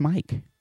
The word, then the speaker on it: MMYYKK: We're going to keep it moving, y'all, with a track by an artist named Mike, but he spells it M-M-Y-Y-K-K. And it's pronounced Mike.